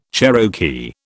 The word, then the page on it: Cherokee: British English